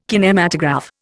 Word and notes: kinematograph: Indian

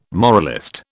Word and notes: moralist: British English

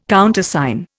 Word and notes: Countersign: Indian

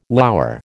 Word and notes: lour: American English

